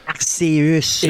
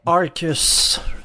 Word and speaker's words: Arceus: On le prononce en français Arceus. Arceus. – Et en anglais Arcus. Arcus. Arcus.